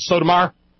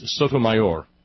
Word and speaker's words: Sotomayor: But around here, we pronounce it Sotomayor. – Yeah. – Sotomayor. But it is Sotomayor.